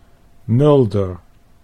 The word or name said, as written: Mulder